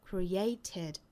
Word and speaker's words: created: 之后加 ed 的发音呢，要加一个 a 的元音在里头 created